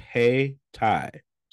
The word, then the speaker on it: Hayti: Haiti is pronounced Hay-Ti, so I pronounced it wrong. So it's Hay-Ti.